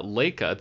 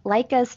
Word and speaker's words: Laika: All right, so let's talk about uh, Laika, the stop motion, or Laika, I think Laika is how you pronounce it.